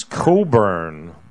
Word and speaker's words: Cockburn: Dead or Canadian, uh, singer songwriter, uh, sang Wondering Where the Lions Are, Bruce Coburn, but it's spelled Cockburn.